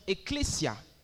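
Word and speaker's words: ecclesia (ἐκκλησία): Rentrez dans le grec et vous allez trouver que c'est un mot grec que l'on prononce ecclesia.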